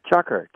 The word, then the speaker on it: chukar: It's a type of partridge, shukar or something like that. Is that how you pronounce it? – Chukar. – Oh, it is. Okay.